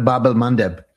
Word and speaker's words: Bab el-Mandeb: Bab el-Mandeb, I'm sure I'm butchering the pronunciation. – No, that's right, the Bab el-Mandeb.